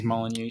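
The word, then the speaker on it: Molineux: Molyneux, they call that. – It is Molyneux Stadium. – Got an X in there. You don't pronounce it.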